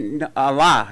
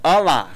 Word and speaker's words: Ahlah: Did you say your name was Ahlah? I said Ahlah. I didn't say Ahlah. I said Ahlah.